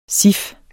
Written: cif

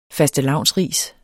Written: fastelavnsris

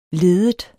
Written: leddet

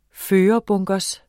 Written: førerbunker

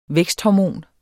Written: væksthormon

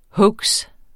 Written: hoax

